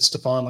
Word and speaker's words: Stephon: Stephon Shivers, not Stephen Shivers. Not that I said that. I asked him specifically, how do you say it? He said Stephon, like Stephon Marbury, and it shivers like being cold.